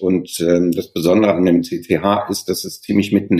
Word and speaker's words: CCH: CCH, Kongresszentrum Hamburg, Betonung auf C, besonders vornehm in Anführungsstrichen.